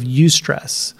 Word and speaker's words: eustress: So the main two types of stress, first of all, we have eustress, and that is spelled E-U-S-T-R-E-S-S, eustress.